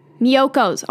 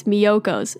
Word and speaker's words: Miyoko's: And Mykonos, I think that's how you pronounce it. Miyoko's. Oh my God, I always call it Mykonos. Oh my God, it's Miyoko's. It's Miyoko's.